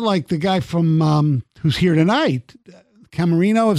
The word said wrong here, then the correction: Caminero: Is that how you pronounce Caminero? – His name?